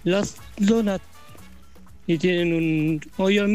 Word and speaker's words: Donut: Donuts, no, se escribe Donut. Y la pronunciación es donuts. La U como una A, pero es una U.